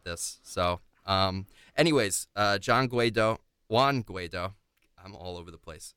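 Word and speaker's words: Guaidó: And his name is Juan Guaido. I do not know how to pronounce Spanish names. So did I get that right? – I'm going to say Guaido. – Guaido. Okay. I'll say that.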